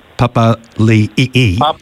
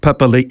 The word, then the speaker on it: Papali'i: Well, hang on, there's a new pronunciation, isn't it? Josh Papali'i. Yes. We've got to change... Obviously, obviously, someone's later complained about the pronunciation, but anyway, Papali'i.